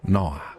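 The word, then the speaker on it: Noah: Com'è sta pronuncia di Noè? – Noa. – Quindi uno dice Noa, non sa che Noè.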